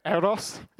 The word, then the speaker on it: Eros: Eros, got to roll the R.